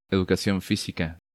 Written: Educacion Fisica

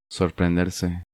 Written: Sorprenderse

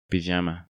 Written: Pijama

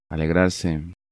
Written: Alegrarse

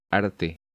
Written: Arte

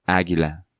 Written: Aguila

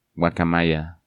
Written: Guacamaya

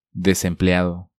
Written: Desempleado

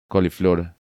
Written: Coliflor

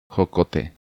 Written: Jocote

